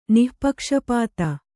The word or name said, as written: ನಿಃಪಕ್ಷಪಾತ